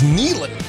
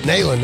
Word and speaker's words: Nealon: They made it clear that it is Nealon. And I was like, ah, okay. I could have swore I grew up around here. I don't know which. I don't know how you say it. I've always said it Nalen until like last year when I realized, oh, I've been saying it wrong all these years.